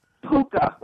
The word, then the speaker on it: Pucca: Spell it. Can you spell it? – Puka, like a puka shell. – P-U-K-A? – No, P-U-C-C-A, Palace.